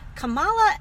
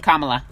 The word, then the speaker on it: Kamala: I just want to also point out that Kamala, and I didn't make... – Kamala. – Whatever. – That, no, no, no, it's important that we pronounce her name right.